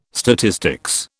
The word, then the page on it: statistics: British English